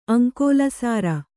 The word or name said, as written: ಅಂಕೋಲಸಾರ